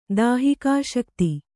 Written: ದಾಹಿಕಾಶಕ್ತಿ

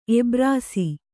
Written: ಎಬ್ರಾಸಿ

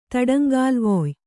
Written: ತಡಂಗಾಲುವೊಯ್

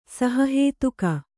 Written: ಸಹಹೇತುಕ

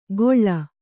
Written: ಗೊಳ್ಳ

